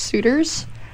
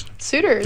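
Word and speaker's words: Suter's: Shout out to my hometown-ish pumpkin patch, Suitors. If anybody's around that, like, Pandora, Gilboa area. – Suitors. How do you spell it? – S-U-T-E-R.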